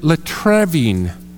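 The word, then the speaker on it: latreuein: Well, to begin with, this word, I would pronounce it latrevin.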